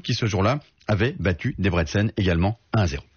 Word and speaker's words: Debrecen: l'équipe de Debrecen, prononcé Debrecen,